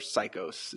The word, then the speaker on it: Psychos: His name is Trevor Psychos, is how you pronounce his last name. So Psycho with an S.